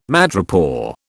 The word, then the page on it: madrepore: British English